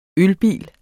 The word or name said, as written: ølbil